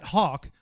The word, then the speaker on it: Hawk: So one of the guys that he's getting that ball back to a lot is Matt Hawk. I'm saying it right? I had to look up the pronunciation. – Yes, sir, yeah. – How often do people butcher your name? – I mean... – On the regular? – On the regular. I mean, even from high school to college to here... I always get Hack.